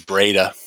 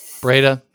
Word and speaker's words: Breda: I think it's Breda. – Breda, yeah.